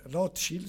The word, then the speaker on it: Rothschild: che era Rothschild, scusate che si pronuncia in questo modo,